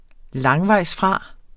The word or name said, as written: langvejsfra